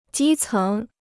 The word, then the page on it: 基层: jī céng